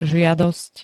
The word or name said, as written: žiadosť